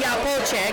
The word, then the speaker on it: Iapoce: I'm here at the IABF 4th Annual Dinner with Anthony, we're going to call him Iapoce, because that's how you say it in Italian, the hitting coach of the Chicago Cubs.